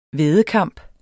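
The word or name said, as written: væddekamp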